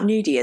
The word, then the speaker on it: gnudi: So, nudie is I hope we're pronouncing it right. I'm pretty sure it's Gnudi. There's a silent G at the beginning.